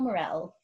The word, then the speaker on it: Maurelle: Oh, it's hello, H-E-L-L-O, then my first name, Morel. Hello, Morel. – And Morel is spelled M-A-U-R-E-L-L-E.